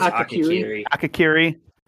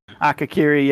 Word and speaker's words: Akakiri: Akari, or the, how do you say it? – I think it's Akakiri. – Akakiri, yeah.